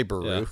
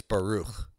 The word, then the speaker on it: Baruch: You know, like Baruch, like you can just say Baruch, yeah. But it's Baruch. Like there's a ch.